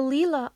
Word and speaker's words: Lila: It's spelled L-I-L-A, but it's pronounced Lila.